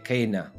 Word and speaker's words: cena: E cena sarebbe, in restituta sarebbe Kena.